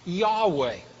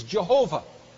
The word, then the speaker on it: Yahweh: We're not precisely sure, but almost certainly it's pronounced Yahweh. There's a word for God that describes him as God in kind of generic terms, Elohim, God, the Mighty One. But Yahweh is his personal name. The older way of pronouncing it, and I won't explain why it's a mistranslation, is Jehovah. And that's based on a misunderstanding of the Hebrew passage.